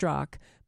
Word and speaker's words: Strzok: Text messages between Peter, I'm going to spell his last name once, Strzok, apparently pronounced Strzok, but this guy Strzok, and this Lisa Page, who was his mistress.